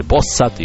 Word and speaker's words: Bossa: BOSSA と書いてボッサと言うんですが、